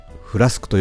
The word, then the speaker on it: FLSK: そうです、このフラスクは魔法瓶のようにお湯は熱いまま、冷水は冷たいまま、長時間の保存ができるんです。冬になったら温かい紅茶とか入れてもいいですね。アルファベットで FLSK と書いてフラスクと読むこのボトル、ドイツのミュンヘンで開発されたもので、デザインやカラーリングもとてもおしゃれです。